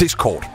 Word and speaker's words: Discord: Det har været underholdende at høre diverse nyhedsoplæsere og tv-værter forsøge at udtale Discord, som bliver ofte og ofte udtalt Discord. – Men siger man Discord? – Discord. – Ja, tak.